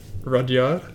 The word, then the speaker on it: Rudyard: Rudyard. – Rudyard. Is that how you say it? – Yeah.